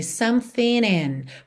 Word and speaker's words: something: Aqui é interessante reparar que ele juntou something em in pelo N, something in. Poderia ser aquela ideia do NH também, something in, something in, já que não pronunciamos essa letra G aqui ok?